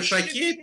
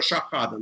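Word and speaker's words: Shahade: Мы ее называем Шахейт, но они произносят ее Шахада, на самом деле.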